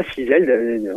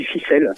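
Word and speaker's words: Fisel: Alors on ne dit pas Fizel, on dit Ficelle. – Ficelle, ouais, d'accord. – Ça se prononce Ficelle, c'est lié au pays Ficelle, petit pays de danse du centre-Bretagne. Voilà.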